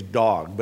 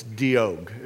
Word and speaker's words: Doeg: I always want to say his name's Dog, but it's Doeg, Doeg, D-O-E-G.